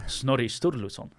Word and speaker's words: Snorri Sturluson: Snorri Sturluson.